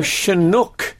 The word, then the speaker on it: Chinook: So, Chinook is what it should be. – Karen Wanamill. Chinook. So, it's always possible that the original pronunciation is with a hard ch sound, of course.